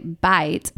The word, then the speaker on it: bite: For instance, in France, you say bite, pronounced like beat, which means penis.